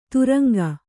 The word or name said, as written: ದ್ರಂಗ